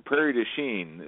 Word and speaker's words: Prairie du Chien: Let's do the soldier from, what is it, Pierre Duchamp? – Oh, Prairie du Chien is how they pronounce it locally.